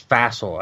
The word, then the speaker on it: Fassel: Fossil, by the way. – The former Giants coach? – No, it's the son of somebody else. It's Fassel, I think, is how you say his last name. It's F-A-S-S-E-L.